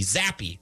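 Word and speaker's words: Zappe: Pete in Chippewa says, Zapp was the freaking QB. Zappy. Sorry, I said it wrong. Zappy.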